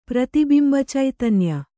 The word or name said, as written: ಪ್ರತಿಬಿಂಬಚೈತನ್ಯ